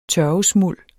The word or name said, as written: tørvesmuld